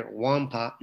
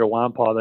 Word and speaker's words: Nwankpa: Nwankpa, the N and the K, are silent, so we will say his name correctly for the rest of the time we cover him finally. Xavier Nwankpa, the number one safety in the top 247.